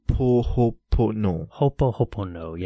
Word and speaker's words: Ho'oponopono: Ho'oponopono.